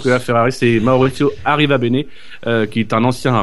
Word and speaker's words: Arrivabene: Alors, Jackie, répète après moi. Maurizio. Maurizio. Arriva bene. Arriva bene.